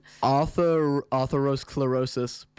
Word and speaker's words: atherosclerosis: Atherosclerosis. Pronounced atherosclerosis. Sclerosis. Is it chlorosis? – Okay, I've been saying it wrong as well.